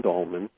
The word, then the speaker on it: Dahlman: It's just D-R-D-A-H-L-M-A-N. D-R in front of my last name, Dahlman.